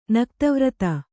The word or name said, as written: ನಕ್ತವ್ರತ